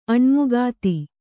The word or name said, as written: ಅಣ್ಮುಕಾತಿ